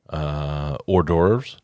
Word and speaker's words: hors d'oeuvres: And it's going to feature networking, hors d'oeuvres. I did not know that's how you spelled hors d'oeuvres. – I still think you're saying it correctly.